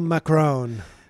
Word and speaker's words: Macron: Emmanuel Macron.